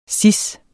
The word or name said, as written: cis